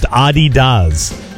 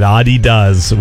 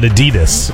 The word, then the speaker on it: Adidas: And it's actually pronounced Adidas. That's how you say it, Adidas, with the emphasis on the first syllable, not Adidas, how we say it here, stressing the second.